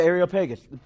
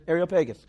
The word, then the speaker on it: Areopagus: How would you say this word? Yeah, Areopagus. That's it. Areopagus. That would be the correct way.